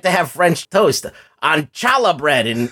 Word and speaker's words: challah: Challah, it's pronounced challah bread.